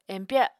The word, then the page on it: empya: Apatani